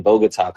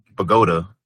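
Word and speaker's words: Bogotá: I went to Mexico for a little bit, and then I was in Bogota, Colombia. – See? See, some people pronounce it Bogota. But fancy people like yourself say Bogota, so I get it.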